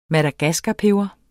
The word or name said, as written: madagaskarpeber